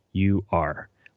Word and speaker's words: Uighur: So Uighurs, it's spelled Uighur. Uighur. So it's a U-I-G sound there.